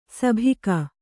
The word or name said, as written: ಸಿಬಿಕೆ